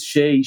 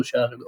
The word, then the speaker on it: Shay: This is very, Shai very Pausner. Unusual. Yeah, very unusual. Always Shai, she, Shu, Shai, all kinds.